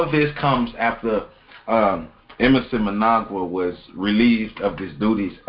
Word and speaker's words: Mnangagwa: And you spell it MNANGAGWA. So we're gonna call him E. Emerson. – No, it's Emerson Mnangagwa. – That's Mnangagwa. All right, there it is.